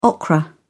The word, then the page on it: okra: British English